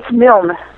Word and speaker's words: Milne: So you could, I'm probably going to mispronounce her name, but you contributed to Carly Milne's, is it Milne? Is that how you pronounce her name? – It's Milne. – Milne? Oh, so it's Milne. – It's American then.